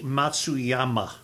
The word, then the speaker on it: Matsuyama: Matsuyama, how are you pronouncing that? – Hideki Matsuyama. – Yeah, ma. I don't do the ma very well.